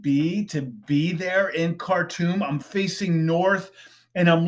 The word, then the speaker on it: Khartoum: Khartoum, the spelling, Khartoum. All right, but it's pronounced like cartoon, like you're watching a Bugs Bunny cartoon, but there's an M at the end, so Khartoum.